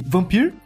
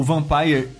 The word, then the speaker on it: Vampyr: mas calhou desses três jogos terem saído meio que com uma, duas semanas separando eles e eu acabei jogando junto, que foi Vampire. Ou Vampire. – Ou Vampire. – Eu vou falar vampiro que fica mais fácil as pessoas identificar, uhum. apesar da pronúncia correta ser o Vampire.